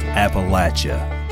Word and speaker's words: Appalachia: It's pronounced Appalachia.